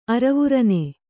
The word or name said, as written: ಅರವುರನೆ